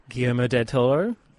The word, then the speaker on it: Guillermo del Toro: I don't know how to say his name. – Guillermo del Toro. – That's the one.